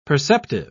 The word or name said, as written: perceptive